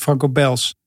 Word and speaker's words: Franco Beels: Je moet echt het Belgisch, ja. – ja. Nou, Franco Beels. Franco Ja, En dan op zijn Brabants uitgesproken, Franco Bels. Met een S ook.